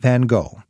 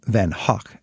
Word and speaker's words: van Gogh: Vincent van Gogh, or Van Hoek, as the Dutchman himself would have pronounced it,